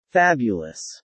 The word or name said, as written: fabulous